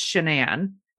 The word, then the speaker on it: Shanann: People have mispronounced her name a lot because it looks like Shannon, but it's Shanann.